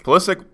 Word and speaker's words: Pulisic: Pulisic plays, how do you say it? – Tottenham.